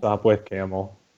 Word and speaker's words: Sopwith: What is it, really the Sopwith Camel? – Sopwith Camel. – Yeah, it's spelled S-O-P-W-I-T-H.